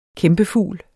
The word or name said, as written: kæmpefugl